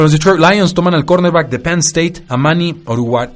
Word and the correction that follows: Oruwariye: siempre me falla este apellido. Oruwariye. Amani Oruwariye. Ah, ahí está, perfecto, ya como africano lo pronunciamos.